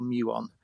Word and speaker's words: muon: I don't know how you pronounce the muon, M-U-O-N. – It's a muon, yeah.